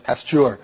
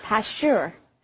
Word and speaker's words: Pasteur: That's P-A-S-T-U-R-E. And his name sounds a lot like that, except the emphasis is on the second syllable, so it's Pasteur. – Pasteur.